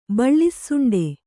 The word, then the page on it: ಬಿಳಿಸೊಂಡೆ: kannada; biḷi soṇḍe